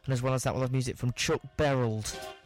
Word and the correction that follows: Chuck Berry: That's Chuck Berry.